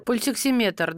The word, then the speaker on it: пульсоксиметр: Э, пульсоксиметр, да? – Пульсоксиметр, да. – Да, видите, я неправильно ставлю ударение.